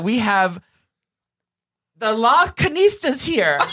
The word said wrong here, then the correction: Las Culturistas: Is that how you say it? – Here's how you say it. Las Culturistas.